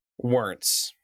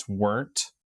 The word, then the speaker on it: Werntz: It's taken me about 40 years to figure out how to explain to people how to pronounce my last name, and here's what I've landed on, is that it's Werntz with a Z at the end.